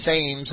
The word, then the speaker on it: Thames: I was corrected tonight. It's the Thames River. I see a T-H and an A, and the English see a T-E. I guess it's their river. I'll pronounce it the way they do.